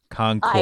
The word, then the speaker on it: Concord: Everybody mispronounces Concord, too. That one bothers me even more. Concord.